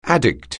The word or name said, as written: addict